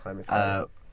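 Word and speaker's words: Clyde McPhatter: This is Clyde McFadder, is that the right pronunciation? – Yep. Clyde McFadder.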